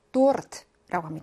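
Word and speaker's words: 도르트라고 (торт): 케이크, 러시아인들이 굉장히 좋아하는 디저트인데, 러시아어로 도르트라고 합니다. 르르 또르할때 굴러야 됩니다.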